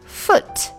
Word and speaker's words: foot: 这个 foot，注意 double o 的发音，它不是 foot，它是 foot, foot。 foot，哦哦哦，不是呜，是哦。